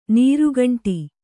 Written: ನೀರ್ಗುಂಡಿ

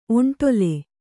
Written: ಒಂಟೊಲೆ

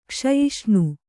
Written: ಕ್ಷಯಿಷ್ಣು

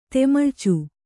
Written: ತೆಮಳ್ಚು

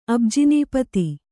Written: ಅಬ್ಜಿನೀಪತಿ

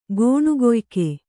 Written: ಗೋಣುಗೊಯ್ಕ

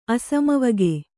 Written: ಅಸಮವಗೆ

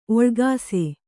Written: ಓಲಗಿಸು

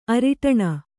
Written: ಅರಿಟಣ

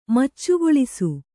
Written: ಮಚ್ಚುಗೊಳಿಸು

